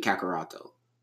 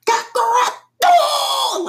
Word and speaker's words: Kakarotto: It is actually Kakaroto. It's K-A. R-A-O-T-T-O. – Kakarotta. – That's why Broly in Japanese, Broly's so fucking annoying in Japanese. Kakarotto!